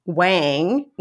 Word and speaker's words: Wang: And that is based on its spelling because the pinyin is W-A-N-G. And when Americans see that, they think it's pronounced like the rhyming with hang or bang. Fang. So that's why it, like in America, everybody that sees our last name, W-A-N-G, pronounces it Wang.